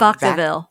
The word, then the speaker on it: Vacaville: First question is from Kathy in Vacaville, California. Forgive me if I said that wrong. – Vacaville. – Vacaville. Thank you.